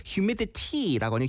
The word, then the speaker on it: humidity: I think, humidity 발음은 그, you know, the American way of like words with T like in the middle, like you don't really pronounce the T. Humidity라고 하는 humidity.